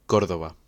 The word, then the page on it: Córdoba: Spanish